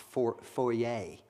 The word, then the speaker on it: foyer: which I recently learned is actually pronounced foyer.